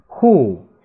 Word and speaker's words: who: then 那时注意贴去咬舌尖 z 嗯 then who 谁注意这个 wh 在这里是 w 不发音发 h 发 h h 发音啊注意有时候它是 wh 发音有时候 h 发音在这里是发 h 这个音 o 呢是个变音词发 u 和 u who grandparent 祖父祖母外祖父外祖母啊，grand 是可以表示祖辈，也可以表示孙辈的啊。